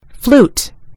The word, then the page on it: flute: British English